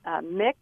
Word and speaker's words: Mx: the prefix MX. She says is pronounced mix as opposed to Miss or Mrs., and that students are to call her by the pronouns they, them, and their rather than he, his, she, or hers.